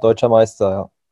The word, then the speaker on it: Deutscher Meister: And you are here to take a victory lap at our expense because you are a newly crowned, I think it's pronounced Deutscher Meister. You laughed at my German pronunciation last time, so that was a real risk for me. – No, that was pretty good, yeah.